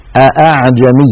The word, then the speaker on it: أأعجمي: أصل هذه الكلمة أعجمي، أعجمي أولها همزة، همزة قطع مفتوحة، دخلت عليها همزة الاستفهام وهي أيضا همزة قطع مفتوحة، فالتقى في النطق همزتان أأعجمي، هكذا الأصل، الأصل أن يقول أأعجمي وعربي، لكن حفصا رحمه الله رواها عن شيخه عاصم أعجمي وعربي بتسهيل هذه الهمزة